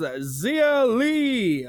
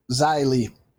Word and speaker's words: Xia Li: So we kick this show off with Mercedes Martinez versus Xia Li. I think I said that wrong. – Xia Li. – That's what I thought. – There you go.